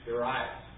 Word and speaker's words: Darius: This is not really important in our teaching, but a number of books that have markings have Darius as Darius, instead of Darius, the accent on the second syllable and the long I, Darius.